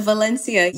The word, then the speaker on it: Valencia: And I like that pronunciation, the Valencia. You stepped into that Castellano accent.